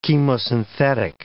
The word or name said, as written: chemosynthetic